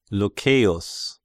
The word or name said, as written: Lucceius